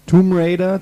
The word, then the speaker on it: Tomb Raider: Tomb Raider, das B wird nicht ausgesprochen und.